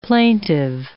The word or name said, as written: plaintive